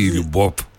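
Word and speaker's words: Λιουμπόφ (любовь): Έτι Λιουμπόφ. Έτι Λιουμπόφ. Θα πει θυμίσου εκείνο τον έρωτα, κύριε Χρυσούλα. – Περίμενε. Ζαπόμνη έτι Λιουμπόφ. – Με β στο τέλος, αλλά προφέρεται F.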